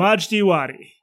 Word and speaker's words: Majdi Wadi: It's called Honestly with Barry Weiss, and her first interview was with a guy named Majid Wadi. Majid Wadi? How do you pronounce it? – Majdi Wadi. – Majdi Wadi. – Yes.